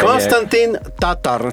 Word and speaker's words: Konstantin Tatar: Right, Konstantin Tatar.